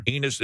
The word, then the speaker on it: Enes: Enes Cantor. – Enes, is that how you say it? Unusual spelling, E-N-E-S. – Uh-huh.